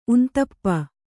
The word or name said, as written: ಉಂತಪ್ಪ